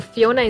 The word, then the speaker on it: Phiona: You can search for Phiona Martin. So Phiona is spelled with a P-H-I-O-N-A. It's not the traditional spelling.